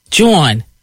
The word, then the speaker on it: jawn: How do you spell that? – J-A-W-N. – Joan.